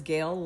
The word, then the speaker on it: Gayle: My name is Gail Long, and Gail is spelled G-A-Y-L-E.